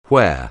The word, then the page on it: where: anglais